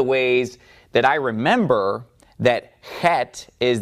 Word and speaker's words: chet: And it's like the Greek he, and it is chet. Eighth letter is chet. All right, the ninth letter rhymes with chet, and it looks like that, and it is tet, tet.